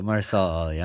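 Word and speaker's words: Mahershala Ali: Mayor Marsala Ali. – Is it Marsala Ali? – Yeah, Marsala Ali.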